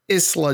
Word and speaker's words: Isla: Let's before we get to the tag team title match, we have Alba Fyre versus Isla Dawn. It's just Isla. you got? – The ass is silent.